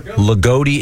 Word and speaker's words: Loogootee: L-O-O-G-O-O-T-E-E. – Ligoti? – Ligoti, Indiana. – Ligoti, Indiana. – Ligoti, yeah. – Sure. – Wow. – And Jess was right. Ligoti is the Lions.